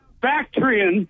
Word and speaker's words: Bactrian: That is pronounced Bactrian and Dromedary. They're two camels.